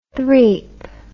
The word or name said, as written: threep